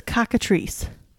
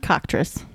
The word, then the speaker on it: cockatrice: Oh, that says cockatrice. That is not cocktress.